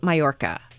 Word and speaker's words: Mallorca: All right, let's move along to Mallorca. Am I saying it correctly? – Yeah.